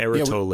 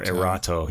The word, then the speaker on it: Erato: On the Erato label. Yeah. – Yeah, we liked these a lot, if I remember. – And, or Erato. He can pronounce it either way.